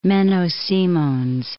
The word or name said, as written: Menno Simons